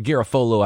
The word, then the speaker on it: Garofalo: Or Garofalo, I believe, is how he pronounces it. – Yeah, correct.